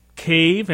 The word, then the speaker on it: Kahve: It's called Cave, and it's spelled K-A-H-V-E.